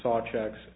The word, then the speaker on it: Szewczuk: So based on that, he also cited a reference, it's I think pronounced Sawcheck, S-Z-E-W-C-Z-U-K, reference to support his findings.